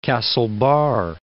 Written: Castlebar

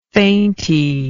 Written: fainty

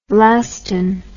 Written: Lasdun

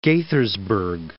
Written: Gaithersburg

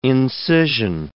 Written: incision

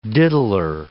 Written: diddler

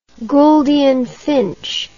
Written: Gouldian finch

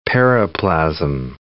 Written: periplasm

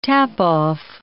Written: tap-off

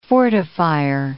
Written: fortifier

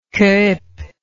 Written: Kuyp

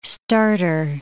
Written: starter

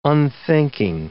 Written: unthinking

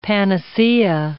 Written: panacea

